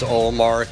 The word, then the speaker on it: Ullmark: Linus Ullmark is their big expenditure.